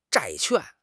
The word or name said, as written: 债券